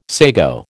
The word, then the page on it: sago: American English